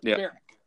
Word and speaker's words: Berwick: And Berwick is actually pronounced in Scotland. This golf course is pronounced as North Berwick. So, like, the barracks, like North Berwick. That's how it's pronounced.